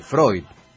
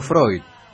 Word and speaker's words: Freud: El diptongo EU en alemán, y el idioma madre, es hoy, Freud. Por eso Freud. – Diptongo. – El diptongo EU de Freud, como dice usted, es, EU. – Se pronuncia hoy.